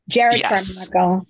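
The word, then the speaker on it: Jerrod Carmichael: Jared Carmichael.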